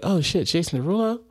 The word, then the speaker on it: Derulo: Jason Derulo. – Jason, you ain't say his name right. You got to sing. – Jason who? – Jason Derulo.